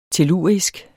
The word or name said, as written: tellurisk